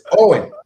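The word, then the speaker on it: Eoin: It's Owen. – It's Owen. – Owen, though. – No, that's the Irish way of spelling Owen. – That's the Irish pronunciation of it. – I'm like, well, Eon.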